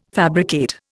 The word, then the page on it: fabricate: Indian